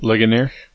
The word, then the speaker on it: Ligonier: You guys debated at, is it Ligonier? How do you say it? – Ligonier.